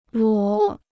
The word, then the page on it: 裸: luǒ